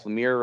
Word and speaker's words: Lemire: Lemire run. I learned that you actually say his name Lemire and not Lemire, so now I said it right.